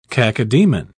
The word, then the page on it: cacodaemon: British English